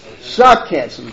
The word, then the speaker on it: sarcasm: At times, their attitude is one of mockery and scarcism, if I'm pronouncing it. How do you pronounce it? Sarcasm, that's the word I want.